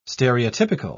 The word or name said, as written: stereotypical